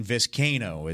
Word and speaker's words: Vizcaino: I believe there's like a weird back and forth on like how to say his name, but I have seen Vizcaino. – Yeah, see, I've seen Vizcaino is how you say it, with Cain, C-A-I-N, capital.